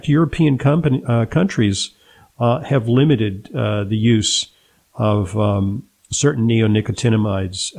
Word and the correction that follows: neonicotinoids: I'm mispronouncing it. It's neonicotinoids.